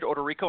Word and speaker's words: Odorico: My name is Ted Odorico, and it's spelled O-D-O-R-I-C-O.